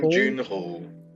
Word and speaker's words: Bong Joon-ho: It is, I always mess up his name. It is Bong Joon-ho. – Bong Joon-ho.